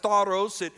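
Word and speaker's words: stauros: Now, the word cross, of course, in the original language, the word is pronounced staros.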